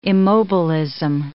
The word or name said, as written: immobilism